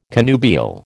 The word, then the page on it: connubial: American English